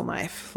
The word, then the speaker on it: knife: And she was always known as the kid who didn't know how to spell knife. Like, oh. Who knew there was a silent K in the beginning of the word knife?